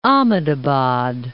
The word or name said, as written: Ahmadabad